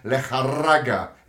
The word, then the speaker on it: Lejarraga: As you said earlier, the two incredible knockouts of German Lejarraga, is how you say it actually, Adam.